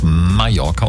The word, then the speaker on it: Mallorca: Eure Lea aus der Morning Show sitzt jetzt gerade auf Mallorca und frühstückt Nutella. Mm. Alles mit Doppel-L wird ja wie J ausgesprochen.